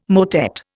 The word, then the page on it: motet: Indian